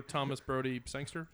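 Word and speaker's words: Thomas Brodie-Sangster: I'll vote for Thomas Brody Sangster. Is that how you say it? – Yeah.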